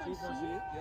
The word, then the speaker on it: Givenchy: Dit is een Givenchy. Givenchy, ja.